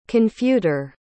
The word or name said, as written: confuter